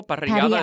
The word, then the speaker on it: parrillada: Parrillada is like a grill off. – P-A-R-I-L-L-A-D-A? – It's with two R's, hence the rolling of the R.